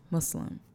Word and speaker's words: Muslim: Very Muslim. So in the documentary, it's a soft S. It's not a Z. – What? – Muslim.